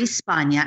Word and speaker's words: Spagna: It's Lori Spagna, and I'll spell it because the G is silent.